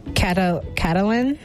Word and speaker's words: Catalan: The official language is, I'm going to butcher it, Catalan? – Yeah, yes.